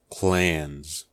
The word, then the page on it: clans: American English